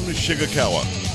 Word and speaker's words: Shigekawa: Shigekawa. – It's spelled S-H-I-G, Shig, E-E-Kawa, K-A-W-A. It's Shigekawa. – It looks like it sounds. It sounds like it looks.